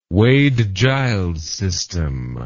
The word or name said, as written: Wade-Giles system